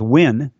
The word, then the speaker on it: Wein: You know, when you see names like W E I N and it's pronounced win instead of when, you know, it's, they're just some that are so close.